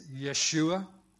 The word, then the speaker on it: Yeshua: And the Hebrew way of pronouncing that is Yeshua, who's there too.